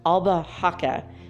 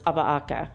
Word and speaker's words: albahaca: You'd think it'd be basilia or something, but no, it's albahaca, but the H is silent, so it's albahaca.